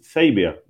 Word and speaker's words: Xavier: But in all Spanish, my name is Xavier, like X Men, the Professor Xavier.